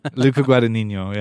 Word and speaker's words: Luca Guadagnino: Is that how you pronounce his name? – Ciao. Yeah, it is. Luca Guadagnino.